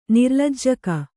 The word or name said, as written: ನಿರ್ಲಜ್ಜಕ